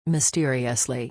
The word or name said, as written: mysteriously